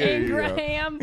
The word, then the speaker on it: Ingraham: Ingram. Yeah. Ingram. – Please, let's pronounce the shitty person's name right. – Ingraham.